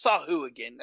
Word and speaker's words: Sahuagin: Yeah, it's Sahuagin. That's how you say it.